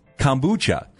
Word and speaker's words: kombucha: Well, I thought it was pronounced kombucha. – Oh. – And it's kombucha. Correct? – Yes. You're right. You're just, again, the emphasis, it's in the wrong place. Oh. – The emphasis is on the wrong syllable.